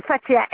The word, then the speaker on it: Fatiha: C'est madame Latif Fatia. – Ça s'écrit comment, madame? – L-A-T-I-F. Latif. – Et le prénom m'avait dit Fatia. – Oui, Fatia, F-A-T-I-H-A, ouais.